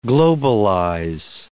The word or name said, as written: globalize